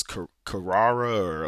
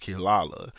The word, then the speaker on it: Kirara: So it's K-I-R-A-R-A. And I remember like when I first actually started watching Inuyasha, I thought that it was Kirara or like Kiara. I didn't know how you said the name, but it's actually pronounced Killala.